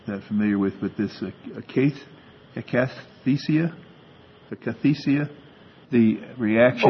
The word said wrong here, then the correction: akathisia: Oh, akathisia, yes.